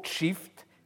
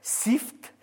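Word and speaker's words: çift: Mais je signale que ce n'est pas ainsi qu'ils sont transcrits couramment dans l'alphabet turc actuel, alphabet turc latin, où ce mot « shift » s'écrira « ccdift ». Mais si je l'avais écrit ainsi, beaucoup d'entre vous le prononceraient « sift », n'est-ce pas ? Ce qui n'est pas la bonne prononciation,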